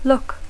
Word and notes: look: English

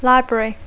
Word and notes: library: English

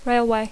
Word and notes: railway: English

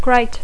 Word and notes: great: English

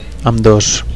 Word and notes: ambdos: Catalan

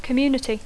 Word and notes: community: English

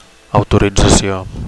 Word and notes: autoritzacio: Catalan

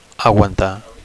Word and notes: aguantar: Catalan

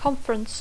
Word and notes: conference: English

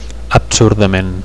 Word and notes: absurdament: Catalan

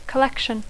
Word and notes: collection: English

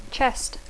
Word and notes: chest: English

